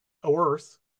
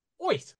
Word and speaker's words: Oerth: which I for years thought was just pronounced Oerth, but apparently it's pronounced like Bugs Bunny with a Brooklyn accent saying Earth. So it's Oith.